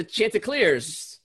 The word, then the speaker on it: Chanticleers: Go – that is, what are they called? Chandeliers? – The Chanticleers. – Chanticleers. – Or the Chanticleers. I don't know how to say it.